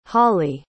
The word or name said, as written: hollie